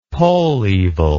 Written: pollevil

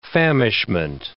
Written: famishment

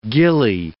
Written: gillie

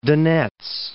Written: Donets

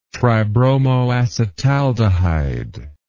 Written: tribromoacetaldehyde

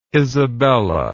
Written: isabella

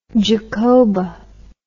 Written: Jacoba

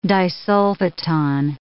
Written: disulfoton

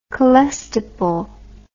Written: colestipol

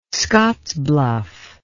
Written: Scottsbluff

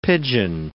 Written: pidgin

